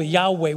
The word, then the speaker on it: Yahweh: And he said, I'm Jehovah, Yahweh. We would pronounce it Yahweh in Hebrew. I'm Yahweh.